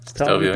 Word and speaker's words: Stelvio: Stelvio Pass.